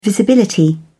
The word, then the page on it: visibility: British English